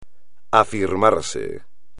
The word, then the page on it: afirmarse: espagnol